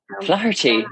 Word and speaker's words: Flaherty: You were telling me in Ireland that they pronounce the H, so I'm trying to. – Yeah. Flaherty. Yeah. Uh-huh. They'll yell at you if you don't, but we Americanize it.